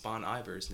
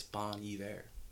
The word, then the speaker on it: Bon Iver: The second thing was we mispronounced Bon Iver's name. Apparently, it's Bon Iver.